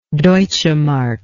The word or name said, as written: deutschemark